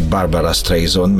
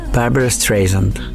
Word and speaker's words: Barbra Streisand: Szándékosan ejtettem így a nevét, hogy Barbra Streisand, mert ugye mindenki így ejti itt szerintem hazánkban, vagy elég sokan. Na de hogyan is ejtik őt szépen? – Barbra Streisand. – Streisand? Vagy Barbra Streisand. Streisand. Igen, egy picit másképp ejtik, mint ahogy mi itt magyarosan szoktuk.